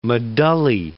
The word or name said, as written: medullae